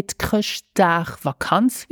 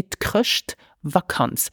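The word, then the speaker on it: Chrëschtvakanz: So be careful, we don't say Kröschtagvakanz, we just say Kröschtvakanz.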